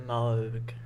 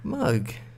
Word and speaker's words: Moog: It's in as in Mo rather than Moo. – So how would you say how does that roll off the lips? – So Moog. – Moog.